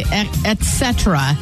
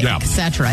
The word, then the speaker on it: et cetera: Now, Tim said the word, for the life of me, I can't say et cetera. He said, I say it like et cetera. A lot of people say et cetera. It's et cetera.